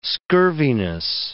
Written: scurviness

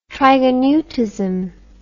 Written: trigoneutism

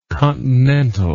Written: continentally

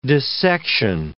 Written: dissection

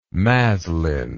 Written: maslin

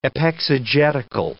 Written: epexegetical

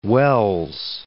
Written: Wells

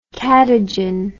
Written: kadigin

